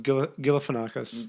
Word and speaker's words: Galifianakis: I think it's pronounced Galifianakis. – Galifianakis. – Well, all right. We'll go with Zach Galifianakis for now.